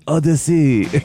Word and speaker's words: Audacy: On the Odyssey app. – Like the French people. On Odyssey. A-U-D-A-C-Y is how you spell it.